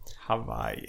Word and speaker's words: Hawaii: Det är ett j som vi har lagt till liksom. – Hawaii. – Ja, det är så det ska uttalas. Hawaii.